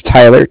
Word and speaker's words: Tyler: My name is Tyler, T-Y-L-E-R, and I'm calling with Cardiff. It's spelled C-A-R. D-I-F-F, as in Frank Frank.